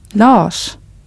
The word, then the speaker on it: Lars: Another point of pronunciation is how you pronounce R plus S. For example, in the word for person or the name Lars. – Person. Lars. – The R and S come together as one single sound.